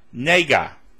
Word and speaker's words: nega: Nega. It's transliterated N-E-G-A, pronounced nega.